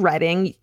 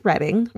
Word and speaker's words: Reading: She was a student in the early 70s in Reading, UK, pronounced Reading rather than reading as in books.